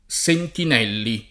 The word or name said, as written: Sentinelli